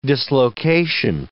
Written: dislocation

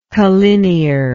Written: collinearly

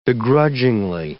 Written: begrudgingly

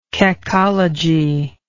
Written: cacology

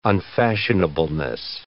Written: unfashionableness

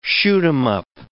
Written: shoot-'em-up

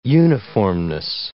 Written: uniformness

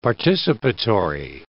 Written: participatory

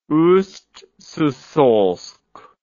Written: Ust-Sysolsk

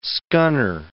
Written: scunner

